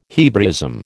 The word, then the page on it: Hebraism: American English